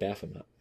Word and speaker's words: Baphomet: Is it called Baphomet or Bahamut or? – I call it Baphomet. – Baphomet. – Yeah. – Why? – That's how it's spelled.